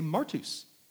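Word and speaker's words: martus (μάρτυς): He'd say martus.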